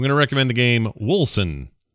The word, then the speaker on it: Wolcen: It's spelled Wilson. I got Wilson, W-O-L-C-E-N.